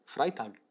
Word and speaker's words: Freitag: How do you say it? – Freitag. – Freitag. – Freitag. – Freitag. – Tag is day.